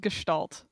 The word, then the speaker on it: Gestalt: I'm sorry, it's pronounced Gestalt.